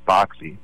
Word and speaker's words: Berkeley: So Berkeley is named after a British philosopher named George Berkeley, whose name is spelled the same as Berkeley, but is pronounced Berkeley.